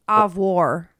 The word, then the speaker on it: au revoir: Thing that we say at the end of the show, we've been saying wrong the entire time. – Au revoir. – Au revoir. It's pronounced au revoir. – Au revoir. – Au revoir. – Au revoir. – Au revoir. – So that's how we were supposed to have been saying it the whole fucking time.